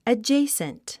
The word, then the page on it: adjacent: ədʒéisnt; アジェイスント